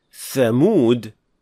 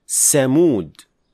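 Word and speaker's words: thamud (ثمود): The final mistake is, when producing tha, some learners mispronounce it as seen. So instead of saying thamud, they would say samud.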